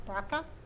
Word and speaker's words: BRCA: She had to have a double mastectomy because in her family background, they had the BRCA, is that how you say it? – Mm-hmm.